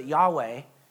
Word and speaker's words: Yahweh: Now, we've added vowels to it, and we pronounce it Yahweh.